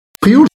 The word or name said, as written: frioul